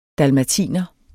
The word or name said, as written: dalmatiner